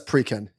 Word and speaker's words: Preqin: Preqin? I'm seeing Preqin in the chat. – Okay. Oh, yeah. It says Preqin.